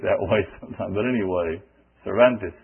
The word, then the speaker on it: Cervantes: Those of us who speak Spanish, excuse me for saying his name that way. The correct way to say his name is Cervantes.